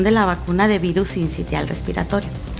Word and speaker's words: sincitial: Entonces, precisamente hoy viene a hablarnos de diversos temas, pero uno de ellos es el virus sincitial, así lo dije bien. – Sincitial respiratorio. – Así.